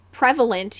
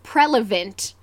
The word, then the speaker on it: prevalent: A rapper, instead of saying prevalent, he says prelevant. And I pronounced it was when I was younger, and so he was trying to rhyme it with like the word relevant or something. Yeah. And so he called it prelevant, and I thought it was prelevant, not prevalent, for years.